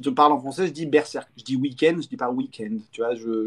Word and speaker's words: Berserk: On, prononce comment on prononce comment Berserk. Moi, je dis. Ah, quand je, quand je, parle en français, je parle avec l'accent français.